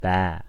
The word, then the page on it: baa: American English